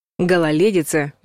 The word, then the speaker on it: гололедица: Гололедица без буквы «ё» и произносится, соответственно, через «е».